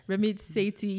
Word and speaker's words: Sethi: I love Ramit Shetty. Am I butchering the name? – Yeah. – Ramit Sethi.